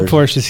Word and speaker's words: Porsche: And then they'd be like, we don't sell Porsches here. – Guys, it's Porsche. – It's a one-syllable word.